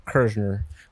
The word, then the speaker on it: Kirzhner: Igor, how do you say your last name? Kirzhner. Kirzhner. – Kirzhner. – Kirzhner, yeah.